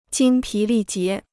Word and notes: 精疲力竭: jīng pí lì jié

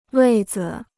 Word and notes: 位子: wèi zi

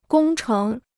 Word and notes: 工程: gōng chéng

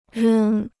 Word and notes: 扔: rēng

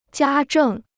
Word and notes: 家政: jiā zhèng

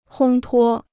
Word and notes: 烘托: hōng tuō

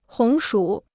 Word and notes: 红薯: hóng shǔ

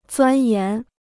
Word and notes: 钻研: zuān yán

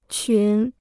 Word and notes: 群: qún

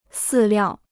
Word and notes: 饲料: sì liào

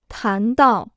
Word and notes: 谈到: tán dào

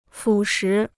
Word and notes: 腐蚀: fǔ shí

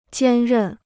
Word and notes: 兼任: jiān rèn

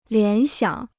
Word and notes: 联想: lián xiǎng

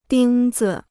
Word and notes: 钉子: dīng zi

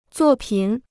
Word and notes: 作品: zuò pǐn

